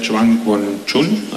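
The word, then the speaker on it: Zhongguancun: We have a collaboration with the Zhuangguanchun, I hope I pronounced this possibly, Innovate Alliance. – You got it.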